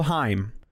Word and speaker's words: Haim: The band is called Haim. – That's how Canadians pronounce ham.